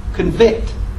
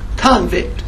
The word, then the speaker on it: convict: That word is pronounced two different ways depending on your usage. There is a verb, convict. There is a noun, convict. One becomes the other because of circumstances.